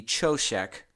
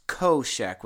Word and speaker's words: Choshek: Okay, so this word darkness, Strong's Concordance number 2822, in English, it looks like it should be Choshek, the way they spell it, but it's actually pronounced Koshek with a K.